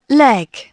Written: leg

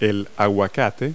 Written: avocado

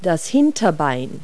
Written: hindleg

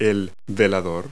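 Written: night table

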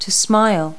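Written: smile

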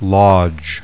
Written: lodge

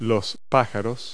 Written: birds

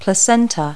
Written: placenta

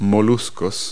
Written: mollusks

